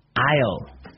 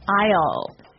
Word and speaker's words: aisle: A-I-S-L-E. – Aisle. – Aisle. It's a tricky word. It sounds like the contraction of I will. – Yeah, but it's spelled so strangely. – Right. We don't pronounce the S. The S is silent.